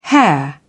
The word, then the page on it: hair: British English